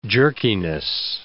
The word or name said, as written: jerkiness